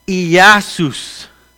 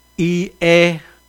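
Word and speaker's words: Iasus (Ἰησοῦς): Estudié un poco cómo se dice en el griego. Es Iasus. Iasus. Iasus en el griego.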